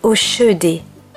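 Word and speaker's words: Hoschedé: Blanche Oskédé Monet. Oskédé, j'ai du mal à prononcer ce nom. Philippe Piguet me reprend. On dit Oshédé et non Oskédé.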